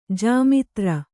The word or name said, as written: ಜಾಮಾತೃ